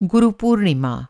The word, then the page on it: गुरू पूर्णिमा: Guru Purnima